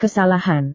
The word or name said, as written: kesalahan